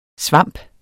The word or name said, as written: svamp